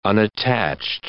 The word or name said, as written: unattached